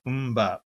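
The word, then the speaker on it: MMMBop: Which band had a hit with Mbop? – Which band had a hit with, you want to spell that for me and use it in a sentence? Yeah, okay. – M-M-M-Bop. M-Bop. That's the proper pronunciation.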